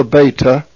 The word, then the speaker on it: beta: It is pronounced beta. It is the B sound, as in the word boy. It's B.